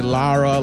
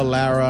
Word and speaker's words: Lara: It's L-A-R-A, Lara, Lara. I always tell people, however you want to say it, it's fine. – Larray. Ian Larray is here, everybody. – Because I'm Spanish, so in Spanish it's Lara. But I'm not going to make you roll your R's.